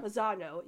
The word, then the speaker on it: Manzano: And speaking of that, the voice of Rosa, Sonia Manzali, is that how you say her name? – Manzano. – Manzano, yeah.